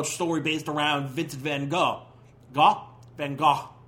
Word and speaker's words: Van Gogh: And with a little. Ch, ch, ch, like, yeah, phlegm in your throat. Van Gogh.